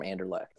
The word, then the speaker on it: Anderlecht: So one player that is going to have a day, it's going to be Francis Amuzu from Anderlecht.